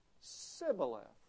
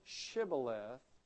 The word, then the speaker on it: Shibboleth: And they say, "Well, okay, say the word Shibboleth." They say, "No problem. Sibboleth." And if they couldn't say Shibboleth, and they could only say Sibboleth, not the sh, but the s, well, then they were not from Gilead, because Gilead, they could say it right, and Shibboleth just basically means a flowing stream.